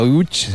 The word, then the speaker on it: Łódź: Aunque se pronuncia Utch, se escribe Lodz, por si alguien lo busca.